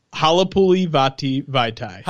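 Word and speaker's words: Halapoulivaati Vaitai: I still remember how you told me how to pronounce that guy's name. – Can you do it for me? – Or what's the name again? One more time. – Halapuli Vati Vaitai.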